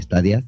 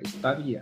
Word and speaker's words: Stadia: Este eh, en teoría, Stadia viene del latín y se pronuncia en español Stadia y en inglés Stadia.